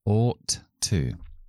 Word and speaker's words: ought to: O sonido largo, la o larga, como la c al revés sin fonética con dos puntitos, ought to. Como ought termina en t y to empieza por t, pues solo voy a pronunciar uno de los dos, si no sería demasiado difícil.